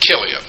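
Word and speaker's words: Chilion: And one translation uses a K instead of a CH, which gives you a hint of how it's supposed to be pronounced. Kilian.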